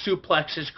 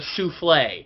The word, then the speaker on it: suplex: One of the reasons why this pay-per-view is better than Starrcade is that Gorilla Monsoon actually pronounces suplexes correctly by calling them a suplex. At Starrcade 83, Gordon Soley keeps calling it a souffle.